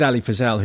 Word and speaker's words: Fazal: You're saying Ali Fazal's second syllable emphasis, and it's a first syllable emphasis.